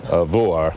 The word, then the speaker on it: Vágar: It looks like Vágar, but how do you pronounce it? – Vuar. – Vuar.